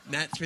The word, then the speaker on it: gnats: gnats occur, and then a whole bunch of people end up dying and stuff. And we just see gnats, gnats, gnats with a silent G.